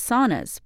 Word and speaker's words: sauna: He believes saunas, pronounced sauna in Finnish, are having a moment, and he expects it to last.